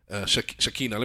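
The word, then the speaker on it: Shekhinah: Shakina. Let me spell it. It's S-H-E-K-H-I-N-A-H.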